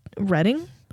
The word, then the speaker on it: Reading: Reading. That's how you say it. – R-E-A-D? – Yeah.